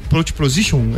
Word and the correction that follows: porpoising: é isso? O, ao ah, porpoising, é, que é aquele que faz é, o, o kick do carro, é isso? – Isso.